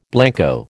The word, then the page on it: blanco: American English